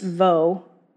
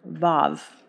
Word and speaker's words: vav: All right, verse, and the next letter looks like wa. It's actually pronounced vo or vav.